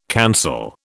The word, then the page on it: cancel: British English